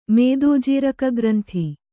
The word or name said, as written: ಮೇದೋಜೀರಕಗ್ರಂಥಿ